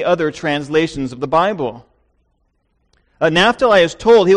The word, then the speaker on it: Naphtali: I've heard it pronounced both ways, by the way. I've heard Naphtali by scholars. I've heard Naphtali. We'll go with one. Here's what he says to Naphtali.